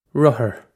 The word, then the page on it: rothar: Irish